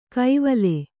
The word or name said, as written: ಕೈವಲೆ